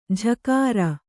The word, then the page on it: ಝಕಾರ: jhakāra